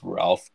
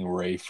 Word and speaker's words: Ralph: Yeah, I don't think it's I don't think it's Ralph though. But whatever. Like when I was looking at the pronunciation, it was like freaking Rafe or something. I have no. – Oh yeah, fear. Rafe Fiennes or Rafe Fiennes. Rafe Fiennes. I mean, that would make sense.